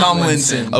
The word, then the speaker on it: Tomlinson: Am I saying that correctly? – Tomlinson. – Okay. I got cyber bullied. I pronounced the L. I said Tomlinson. Then I said Tomlinson. I said Tomlinson. – Oh, yeah. – They were telling me that it was Tomlinson. And I said, yes. No, because this video exists. – Louis Tomlinson. – He's not pronouncing an L.